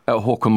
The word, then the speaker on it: Haakon: Haakon Magnus.